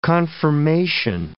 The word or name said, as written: confirmation